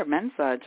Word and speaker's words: Mensah: You are Mensah, just like good.